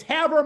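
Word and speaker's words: Havre: It's Haver, Montana, right? – Right.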